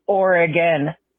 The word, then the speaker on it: Oregon: It's Oregon. Thanks, guys. – So, I mean, overall, I think a good voicemail. And you do need to pronounce Oregon correctly. You call it Oregon. – How do we spell it? – O-R-E-G-O-N. Now, if it was O-R-E-G-O-N-E, maybe Oregon would make sense. But Oregon. Just say it. Let it roll off your tongue real quick.